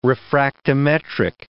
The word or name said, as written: refractometric